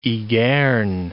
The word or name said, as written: Ygerne